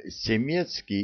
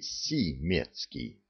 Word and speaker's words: Семецкий: А он Семецкий или Симецкий? – Он через «е» пишется, безнадежно вздохнул я.